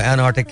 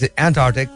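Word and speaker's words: Antarctic: बहुत बहुत different pronunciation है इसके अंटार्कटिका है जो सदन लोग होते हैं वो एनआर्टिक कहते हैं it's एंटार्टिक but you know एंटार्टिक मीन्स एंटी आर्टिक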